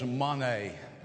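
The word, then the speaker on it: mone (μονή): The word there in the Greek is mone, okay? Mone, pronounced M-O-N-A-Y.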